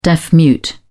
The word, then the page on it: deaf mute: British English